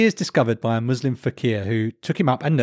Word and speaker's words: fakir: Fakir, F-A-K-I-R, fakir.